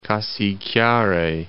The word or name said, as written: Cassiquiare